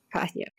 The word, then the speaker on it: Cahya: Escreve C-A-H-Y-A. – Karya.